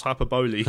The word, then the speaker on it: hyperbole: Alex Clark says, apologies for the nitpicking, but I think hyperbole is pronounced hyperbole.